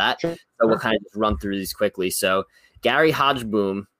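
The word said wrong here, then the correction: Hogeboom: I don't know. Did I pronounce that right? – I think it's Hogaboom, but I'm like, Hogaboom.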